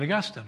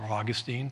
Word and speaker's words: Augustine: And it was Augustine, St. Augustine, or Augustine, pronounce it either.